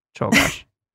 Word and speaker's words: Czolgosz: That's how anyone knows who Shogosh is. – That's why people know how to pronounce Czolgosz. – Oh, whoops. Czolgosz.